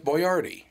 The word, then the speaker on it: Boyardee: No, that's it's B-O-A-R-D-I. – Yeah, they did that because people couldn't pronounce Boyardee, so they changed it to Boyardee.